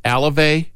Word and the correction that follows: Alzolay: Adbert Alzolay. – Alzolay. There's an L in the front there.